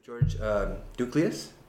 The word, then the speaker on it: Douklias: Yeah, today I'm sitting here with George Douklias.